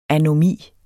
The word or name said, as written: anomi